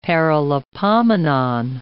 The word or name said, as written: Paralipomenon